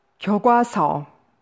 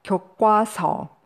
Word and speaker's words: 교과서: 교과서, 교과서. Note, often pronounced 교과서, though technically speaking, this is not standard.